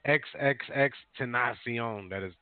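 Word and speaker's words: XXXTentacion: staying in at number three, XXX Tentacion. That's really how you say his name.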